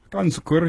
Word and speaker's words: Hakan Şükür: Hakan Şükür. – From where? – Turkey. Try it. Is that pretty close? – Hakan Şükür, yeah.